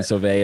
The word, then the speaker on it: Sauvé: Well, my name is Brian Sauvé, as Joel said, and you nailed the pronunciation, by the way.